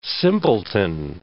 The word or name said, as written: simpleton